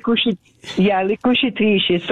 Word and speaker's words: Le Cucitrici: Le Cucci. I'm not saying that right. Can you say that? – Le Cucitrici.